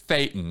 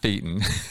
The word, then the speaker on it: Thetan: In terms of the acclimation and all, oh, and then the other thing is it's Thetan, not Thetan. – Ah, yes, important. Yeah. – And the way to remember that is it rhymes with Satan, and that's not a coincidence.